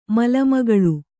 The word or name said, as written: ಮಲಮಗಳು